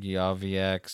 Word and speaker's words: Yozviak: Joey Yozviak. – Spell that last name for me, please. Thanks. – Y-O-Z. – Wait. Y-O-Z. – V-I-A-K.